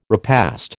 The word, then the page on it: repast: American English